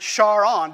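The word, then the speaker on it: Charon: So that's why many astronomers, and you heard Andy pronounce it this way too, pronounce it Char-On, because that's how Jim pronounced it, because he named it after his wife.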